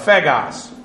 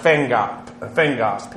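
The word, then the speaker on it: phengos (φέγγος): You got this unusual word, it's only used twice in the Greek, of phagos. And actually, in terms of Greek transliteration, it would be pronounced phengos. P-H-E-N-G-O-S would be the way you would pronounce it, phengos.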